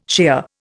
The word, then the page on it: shear: Indian